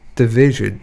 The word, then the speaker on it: DVSN: and that is by Division.